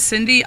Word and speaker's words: Cyndi: Yeah, so my name is Cindy. I spell it C-Y-N-D-I, like Cindy Lauper. Henry's last name is Size, German name. S-E-I-Z.